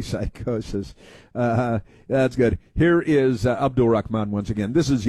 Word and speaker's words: Abdul: We gotta say it like that, man. It's creepy. Abdul. – That's how it's pronounced.